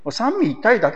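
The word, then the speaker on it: 三位一体: これをみと、いじゃなくてみと、あの、読む。三味一体だけですね、このみと読むのは。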